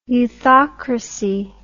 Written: youthocracy

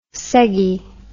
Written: seggie

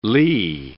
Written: lee

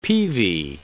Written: peavy

